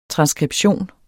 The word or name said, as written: transskription